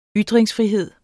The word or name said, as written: ytringsfrihed